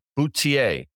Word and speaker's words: Boutier: You, me, Danny Moses, with Celine Boutier. Yeah. Did I say that right, Danny? – Yeah.